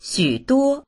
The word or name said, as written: xu3duo1